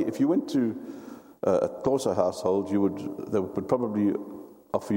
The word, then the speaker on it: Xhosa: And another tribe that we have close to home that is moved southwards into the Cape is called the Xhosa. That's spelled X-H-O-S-A, and you do a click for every X.